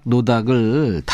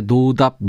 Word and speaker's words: 노닥: 이 노닥 노닥을 답이 없다는 듯의 노답 노답으로 들으셨나 봐요. 제 발음이 시원치 않았군요. 이 시간은 노닥거린다 할 때 그 노닥 노닥입니다.